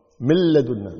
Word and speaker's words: من لدنا: من لدنا، من لدنا، من رحمته، من رحمته، مر مر على طول، من رحمته، من لدنا ما في نون، مل ميم لام على طول مباشرة مكتوب النون لكن ما تلفظ.